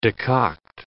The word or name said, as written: decoct